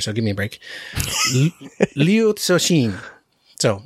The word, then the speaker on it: Liu Cixin: Cixin Liu, something like that, is what I would usually say. But I think, as I understand it, I looked up like how to pronounce it, and as far as I can tell, it's Liu Cixin.